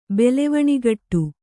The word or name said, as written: ಬೆಲೆವಣಿಗಟ್ಟು